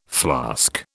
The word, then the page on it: flask: British English